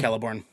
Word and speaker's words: Celeborn: Yeah, Celeborn. – Yeah. Yeah. Which, for my entire life, finding out that they pronounce their C's like that fucked me up, because for the longest time I thought it was Celebrimbor and Celador.